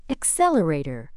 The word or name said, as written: accelerator